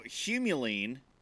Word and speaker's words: humulene: So humulene is an essential oil that get.